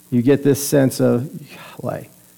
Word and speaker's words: Yahweh: So it's spelled YHWH, but we pronounce it as Yahweh.